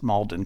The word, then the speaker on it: Maldon: It's actually pronounced Maldon because it's from the English town of Maldon.